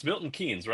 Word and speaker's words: Milton Keynes: And all the way from the UK, and I believe I'm going to be pronouncing it's Milton Keynes, right? That's how you pronounce your town.